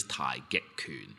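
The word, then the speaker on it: Tai Get Kun (太極拳): In Cantonese, Tai Get Kun, right? Because uh, Tai Chi in Cantonese is Tai Get Kun, right? – Right. – And Tai Chi Chuan is Mandarin, right?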